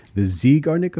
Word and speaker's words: Zeigarnik: One of the most fascinating things you write about is the Zeigarnik effect.